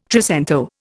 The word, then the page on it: trecento: Indian